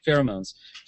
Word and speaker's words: pheromones: The Orion slave girl gives off these pherems that is that the correct pronunciation? – Pheromones.